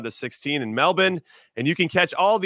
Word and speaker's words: Melbourne: What's funny is that you have Brisbane, which is spelled with a B-A-N-E, and then you have Melbourne and it's still pronounced the same. Brisbane and Melbourne.